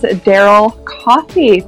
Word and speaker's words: Daryl Cioffi: And to help me with that today is Daryl Cioffi.